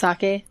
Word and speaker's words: sake: Is it sake or sake? – It's pronounced sake.